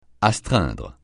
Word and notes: astreindre: francais

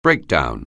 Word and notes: breakdown: anglais

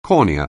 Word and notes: cornea: anglais

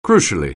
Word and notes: crucially: anglais

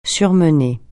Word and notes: surmener: French

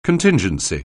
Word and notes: contingency: anglais

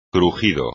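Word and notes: crujido: español

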